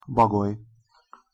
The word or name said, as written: bagoly